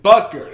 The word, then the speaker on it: Butker: Butker, there you go.